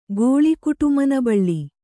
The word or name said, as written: ಗೋಳಿಕುಟುಮನಬಳ್ಳಿ